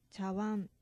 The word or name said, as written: chawan